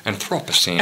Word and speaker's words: Anthropocene: So now, you know, that notwithstanding, whether it's Anthropocene, or as the British say, Anthropocene.